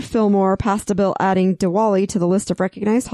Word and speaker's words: Diwali: So I want to talk about the Diwali, however you say it. – Diwali. – So I did say it right the first time.